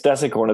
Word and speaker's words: decacorn: Now I want to talk about a decacorn. What is that the right pronunciation? Is it desicorn? – I think it's desicorn. It's kind of a name I made up.